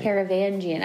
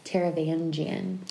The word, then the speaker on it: Taravangian: Taravangian. I didn't know how to pronounce it. Taravangian.